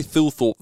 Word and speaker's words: Thilthorpe: Riley Thilthorpe, the man who sounds like you've got a lisp when you pronounce his name, had a dream debut on the weekend for the Adelaide Crows.